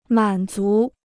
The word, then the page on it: 满足: mǎn zú